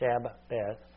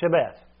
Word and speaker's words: Shabbat: And so I'm going to focus on the Hebrew word for Sabbath, which is Shabbat, or Shabbat. I think that's how you pronounce it. Shabbat.